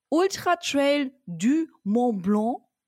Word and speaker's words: Ultra Trail du Mont Blanc: Und zwar warst du beim Ultra Trail du Mont Blanc. War das schön mhm, ausgesprochen? Sehr gut ausgesprochen.